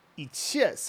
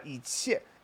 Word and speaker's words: 一切: And by the way, 一切 is a situation where the pronunciation of the character changes. 切 by itself is first tone, and that means to like cut vegetables or something like 切.